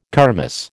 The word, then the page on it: kermis: American English